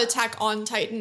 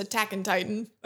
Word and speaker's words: Attack on Titan: Yeah, it's actually not Attack on Titan. That's a common mispronunciation. It's Attack on Titan.